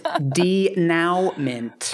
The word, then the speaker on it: denouement: It's pronounced denouement.